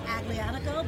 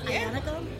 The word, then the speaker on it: Aglianico: It's made out of a South Italy style grape called, phonetically it's Aglianico, but it's pronounced Ionico.